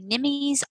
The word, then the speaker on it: Nimmy: Nimmy's Art.